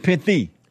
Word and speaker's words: pithy: You may have said piffy. – Yeah, I said Sunday, keep it piffy. – Right. – But pithy.